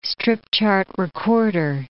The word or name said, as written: strip-chart recorder